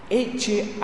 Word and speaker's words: ecce: En la misa, en latín, quizás algunos de ustedes hayan escuchado esta frase, se dice así: ecce agnus Dei. Ecce, como en italiano, eco, y acá está, agnus, el cordero, Dei, de Dios.